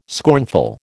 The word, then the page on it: scornful: American English